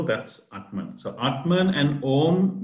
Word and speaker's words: Aum: So Om, O-M, actually pronounced Om, A-U-M, Om, Om. So when you pronounce it, it's pronounced Aum, A-U-M, if you write it down.